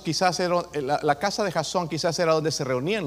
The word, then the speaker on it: Jasón: En inglés es Jason. ¿Ustedes lo pronuncian mal? No, en español se dice Jasón. Ahí tiene un acento en la O, ¿verdad? ¿Lo han visto? Jasón. En inglés es Jason, ¿ok?